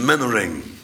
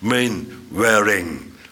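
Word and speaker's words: Mainwaring: Mr. Mannering. – Ja. – Dat is plat uitgesproken, maar dat hoort zo in het Engels. Main Waring.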